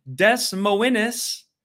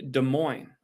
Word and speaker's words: Des Moines: because we have Des Moines. Is how some people pronounce it, but it's actually Des Moines. I was corrected last year on that.